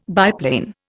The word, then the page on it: biplane: Indian